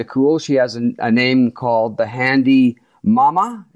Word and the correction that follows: Handy Ma'am: Did I say that right, Shelley? – Handy Ma'am.